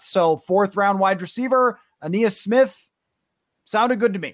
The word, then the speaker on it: Aeneas: Aeneas Smith from Texas A&M.